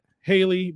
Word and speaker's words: Halle: How do I say it? – Halle Bailey. – Oh, Halle Bailey. – Halle Bailey. – It's spelled differently. It's weird. If it was Spanish, it'd be Haye Bailey.